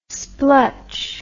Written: splutch

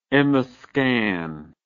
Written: emiscan

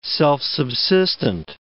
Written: self-subsistent

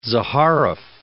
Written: Zaharoff

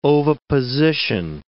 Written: oviposition

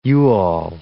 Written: you-all

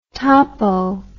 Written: topo

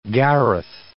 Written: Gareth

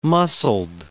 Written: muscled

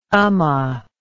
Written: ummah

